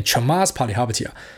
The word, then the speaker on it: Chamath Palihapitiya: 就是之前 GME 的一个意见领袖啊，这个 Chamath Palihapitiya 啊，就后来知道他念 Chamath Palihapitiya。